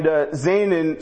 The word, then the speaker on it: Zaanan: And Zaanan sort of rhymes with the word came not forth, Yaseah, but not really.